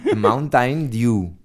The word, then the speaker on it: Mountain Dew: Lo has dicho demasiado bien. Mountain. – Mountain Dew. – Mountain Dew. Mountain Dew. Dew suena como hacer, que es rocío.